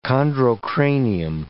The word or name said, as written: chondrocranium